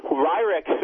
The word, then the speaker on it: Weyrich: You quote, is it Paul White, how do you say his name, Weyrich? – Paul Weyrich.